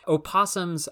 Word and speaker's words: opossum: So if you're reading scientific literature, opossum is the correct pronunciation and spelling of this animal, Didelphis virginiana, and all of the American opossums.